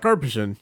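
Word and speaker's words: Karpyshyn: which is Drew Carpishan. I think I'm pronouncing it right. Carpishan. Yeah, I'll buy that.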